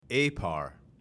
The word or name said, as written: APAR